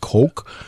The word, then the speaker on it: Koch: K-O-C-H. He pronounces it, sometimes it's pronounced Coke. But he pronounces it Koch.